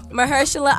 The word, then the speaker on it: Mahershala: And also, Green Book won for the musical comedy motion picture, as well as Mashara. I'm about to butcher this. – Mahershala Ali.